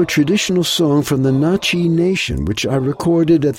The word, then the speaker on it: Natchez: Now, the name of the tribe is pronounced Na'Chi, but it's spelled just like the city of Natchez.